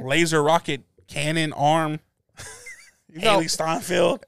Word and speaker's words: Hailee Steinfeld: By Hailee Steinfeld. – It's good. – I hope I'm saying the name right. – Yeah, it's Hailee Steinfeld.